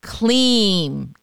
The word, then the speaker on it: Kleem: It's a single word, KLEEM, K-L-I-M, pronounced K-L-E-E-M.